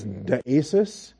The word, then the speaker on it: deesis: The word for supplication is deesis, D-E-E-S-I-S, deesis. That's the short E, epsilon, followed by the long E, eta, D-E-E-S-I-S.